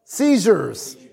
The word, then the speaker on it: seizures: I'm, it, part of these definitions is the word Caesars. Am I saying that right? Seizures. Seizures. There you go.